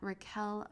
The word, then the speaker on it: Raquelle: Feel free to follow me at Raquel Mantra, R-A-Q-U-E-L-L-E, Mantra.